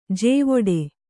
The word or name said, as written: ಜಾವಡಿ